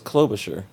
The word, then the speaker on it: Klobuchar: Is it Kloblocker or Kloblocksler? – I thought it was Klobuchar.